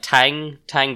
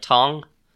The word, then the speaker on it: Tang: Now, word has been going around among demons about I've been saying, like, Tang Dynasty and Tang Monk for this entire podcast. I think it's actually meant to be pronounced more like Tong.